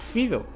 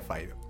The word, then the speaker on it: Fido: Decía, una película canadiense, independiente, del año 2006, llamada Fido, o Faido, en realidad, se pronuncia Faido, que es de Andrew Curry, sí, como la especia, pero sin la Y.